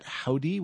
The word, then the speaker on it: Hovvdy: I've got Wendy Eisenberg, and kicking off this set, I've got Howdy, which is spelled H-O-V-V-D-Y. The album is Heavy Lifter, which came out in October of 2019.